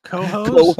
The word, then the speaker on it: Cohoes: Yeah, it's spelled C-O-H-O-E-S, like hose. – Cohost.